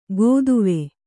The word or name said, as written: ಗೋದುವೆ